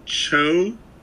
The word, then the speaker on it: Cho: Um, it's with John Cho.